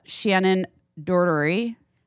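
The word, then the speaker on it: Shannen Doherty: And Shannon Doherty.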